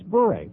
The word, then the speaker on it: Bure: that it's not boring, it's bury. It's a very simple two-syllable word. The coach says bure, Rimmer says bore. It's bure. Very, very simple.